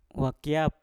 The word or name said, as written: wakiap